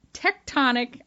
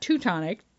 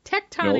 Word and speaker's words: tectonic: This is a Hillary Clinton election is a tectonic, I'm going to say it right this time. – Yeah. – Not Teutonic, tectonic nope.